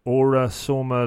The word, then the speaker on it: Aura Soma: There's one I read around Aura Soma Level 3 Practitioner. Is that pronounced right? – Yeah, that's perfect.